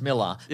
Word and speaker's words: Millar: Explain yourself, Mark Millar or Millar. – That's right. Here's the thing. I know it is pronounced Miller, but we've said Millar too many times to go back now.